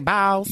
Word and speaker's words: Biles: Biles? – Yes.